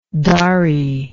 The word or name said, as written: dari